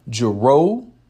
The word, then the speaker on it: Jerreau: Jero, All Night. Jero's name is spelled J-E-R-R-E-A-U.